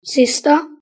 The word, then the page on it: sister: British English